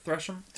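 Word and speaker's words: Tresham: How do you say his last name? – Thresham? – Thresham, that's right.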